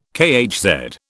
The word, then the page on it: kHz: British English